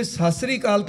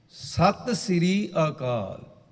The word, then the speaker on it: सत श्री अकाल: फिर तो फिर सत श्रीकाल तो होंगी हो नहीं, ये शब्द नूं असीं बहुत बिगाड़ के रसना तो उच्चारण करते हैं। अपनी जबान शुद्ध करना चाहिए। सत श्री अकाल, मैं कहा खान साहब ये शब्द इउं है,